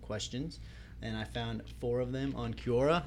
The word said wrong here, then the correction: Quora: I think I'm saying that right. Cura? Cura? – Quora. – Quora. Quora.